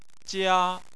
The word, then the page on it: jia: 1